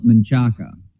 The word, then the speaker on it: Manchaca: I've never appreciated that pronunciation of things. Like, I understand where the Kevin Dunns of the world are coming from with Miller instead of Mueller. Like, that was the pronunciation back in the day. And Manchac kind of is too, but that's like more of like a rednecky pronunciation of a completely different word that's not anywhere close.